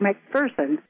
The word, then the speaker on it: McPherson: Saying goes, or the slogan is, that there's no fear in McPherson, so therefore we would pronounce it McPherson.